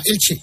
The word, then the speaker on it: Elche: Eh, es una palabra, Elche, dos sílabas, Elche. El problema es la primera sílaba, o sea, el.